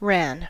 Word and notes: ran: American English

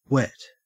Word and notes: wet: Australian English

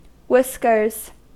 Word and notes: whiskers: American English

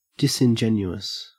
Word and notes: disingenuous: Australian English

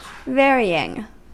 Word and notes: varying: American English

